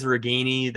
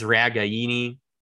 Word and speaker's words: Ragaini: So if you look at the Iowa media guide, it says Reganey. That's been the official pronunciation that Iowa has provided these last few years. We found out this year that the Italian pronunciation, the proper Italian pronunciation, is Ragaini.